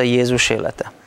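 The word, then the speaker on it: Jézus élete: În limba maghiară se pronunță Iezus Elete.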